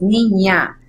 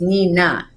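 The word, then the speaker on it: Niña: The Nina is another letter in the Spanish language. It has like a little, I don't know how you call it. I know the little thing. – There you go. So it's pronounced differently. It's Nina, and it's two N's, so it's Nina.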